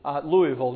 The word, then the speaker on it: Louisville: Louisville, not Louisville, as we say back home. Now, up there in Louisville, but up here it's Louisville. So I'm trying to learn how to pronounce things correctly the Kentucky way.